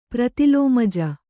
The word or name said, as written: ಪ್ರತಿಲೋಮಜ